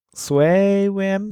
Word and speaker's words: Sway: So, ich kann das nicht aussprechen, das Wort, deswegen SwayWM. – Ja, SwayWM. VM wie Window Manager und Sway wie Sway.